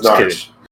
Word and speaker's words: Norwich: It's spelled N-O-R-W-I-C-H. – It does spell. – And it was, but it's pronounced Narch. Narch.